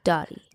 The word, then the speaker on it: Doti: Her name is Dottie by Francis, but low-key people always think it's called Do It by Francis. – Which is so interesting because it's spelled D-O-T-I. And it's not a font that's like confusing to like understand or read. But listen, I love you all. I appreciate the love. But like, Dottie.